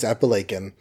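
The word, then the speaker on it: Apalachin: It's not Appalachian. It's spelled differently. It's Appalachian.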